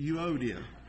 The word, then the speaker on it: Euodia: I urge Euodia, I look these up, and that's how you say it, Euodia.